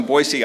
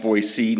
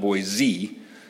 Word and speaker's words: Boise: And it's pronounced Boise, not Boise. If you say Boise, they know you're not from around there.